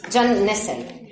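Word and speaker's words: Joan Nestle: Que yo no sabía pronunciar eso, porque es que se escribe, es muy gracioso, se escribe Joan Nestlé, pero es que Joan Nestlé es un yogur. Entonces digo, pero se pronuncia Nessel, Joan Nessel.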